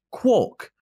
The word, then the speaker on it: quark: However, it was originally going to be pronounced quark, as in pork.